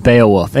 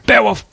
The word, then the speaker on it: Beowulf: Beowulf. – I am Beowulf. He tries to say Beowulf like it's a one syllable word.